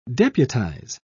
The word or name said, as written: deputize